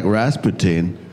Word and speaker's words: Rasputin: And I also wanted to meet a guy who looks a bit like Rasputin.